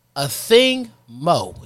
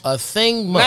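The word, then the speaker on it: Athing Mu: Everybody is pronounced this way. A Thing Mo. It's spelled Moo. – Nice. – A Thing Mo.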